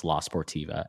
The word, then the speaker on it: La Sportiva: Or I think I said La Sportiva and it's La Sportiva.